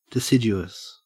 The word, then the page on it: deciduous: Australian English